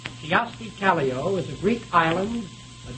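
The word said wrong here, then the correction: Kyösti Kallio: I'll repeat that. Kiyosti Kallio, I'll spell that, K-Y-O-S-T-I-K-A-L-L-I-O, is a Greek island, a dictator of Peru, president of Finland, or the name of a Japanese political party?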